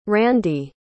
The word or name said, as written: Randi